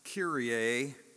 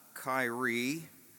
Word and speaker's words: Kyrie: And it's pronounced Kyrie, not Kyrie, as the NBA player Kyrie Irving uses it.